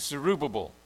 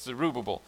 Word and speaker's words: Zerubbabel: Then the angel said, "This is God's message to Zerubbabel." Sorry, I had that name wrong. It's Zerubbabel.